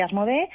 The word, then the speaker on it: Asmodee: Sí, ah, bueno, se pronuncia Asmode, efectivamente.